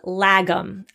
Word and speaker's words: lagom: We're going to move on to Sweden, and I'm so excited because there is a Swedish idea and it is called lagom. I believe I'm pronouncing that right. I did listen to YouTube videos telling me how to pronounce this word.